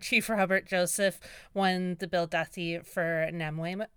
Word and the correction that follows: Namwayut: Am I saying that right? – Namwaiut. – Namwaywet, thank you.